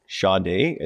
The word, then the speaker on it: Sade: Well, I think her name is Sade, is how you pronounce it. – Okay. Like Bourgeois. – Sade, but S-A-B-E.